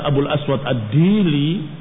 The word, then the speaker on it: Abu al-Aswad ad-Du'ali: Abu al-Aswad ad-Du'ali atau ada yang membacanya Abu al-Aswad ad-Dili adalah orang yang pertama mengusulkan kepada Ali bin Abi Thalib untuk menulis dengan harokat dan menulis dengan i'rab.